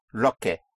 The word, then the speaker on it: lo que: what is lo que, lo que, lo que, like a lock, lo que, but spelt in two words, lo, L-O, and que, Q-U-E, lo que, but pronounced lo que, lo que.